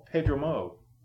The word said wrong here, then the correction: Chance Perdomo: Pedro Mo? Oh, fuck, I can't spell. Pedromo? Middle. – Chance Perdomo. – Oh my gosh, that was butchered pretty bad.